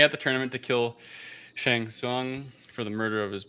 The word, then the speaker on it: Shang Tsung: The Emperor's sorcerer, Shang Tsung, I hope I'm pronouncing it right. I don't know. – Correct. Shang Tsung. Yep. You got it.